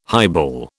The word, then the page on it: highball: British English